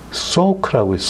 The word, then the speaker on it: Salk: 이것도 한번 들어두시면 이제 좋을 만한데 아, 소크라고 있어요. S A L K. 근데 L은 발음 안 하고 소크인데 이 조나스 소크예요.